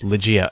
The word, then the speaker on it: Ligeia: Well, you know, there's one example, I think it's pronounced Ligeia, L-I-G-E-I-A, where it's, the house takes on these kinds of personalities.